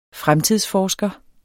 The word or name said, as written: FREMTIDSFORSKER